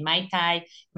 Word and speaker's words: Maite: So they say Maite. And it doesn't end with an A.